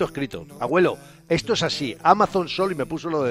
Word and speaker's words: Amazon: Oye, enhorabuena, has dicho Amazon. – ¿Lo he dicho bien? – Sí, es que normalmente decía...